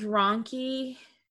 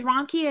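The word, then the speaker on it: rhonchi: But I feel like with ronchi, which is spelled really weird, right? It's R-H-O-N-C-H-I. Mm-hmm. But with ronchi, I feel like, well, I guess it depends on how bad both of these are.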